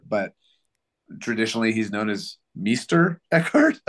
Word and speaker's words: Meister Eckhart: and it says Meister Eckhart.